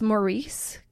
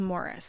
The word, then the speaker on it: Maurice: Right away, so I always thought it was Maurice Gibb, but she says it's pronounced as Morris. So I'm going to call him Morris.